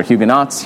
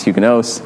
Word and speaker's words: Huguenots: Huguenots, yes, or Huguenots, Huguenots. Depends if you want to be correct French pronunciation or not.